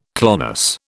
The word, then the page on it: clonus: British English